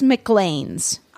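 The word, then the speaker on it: Maclean's: It's McLean's.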